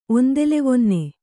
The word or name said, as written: ಒಂದೆಲೆವೊನ್ನೆ